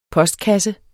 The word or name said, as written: POSTKASSE